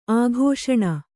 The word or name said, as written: ಅಕ್ಷೂಣ